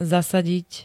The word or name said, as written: zasadiť